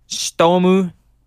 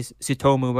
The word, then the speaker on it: Tsutomu: So the case is um is of Tsutomu Miyazaki, which is probably how we'd say it in English, but um Stomu Miyazaki, Stomu, Stomu. That's how they say it in Japanese, but we say Sutomu, but Stomu Miyazaki.